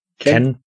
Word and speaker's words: Kean: Und Koulibaly kann sich ja auch bei Kean, wie spricht man das aus? Du bist der Fachmann? – Ken.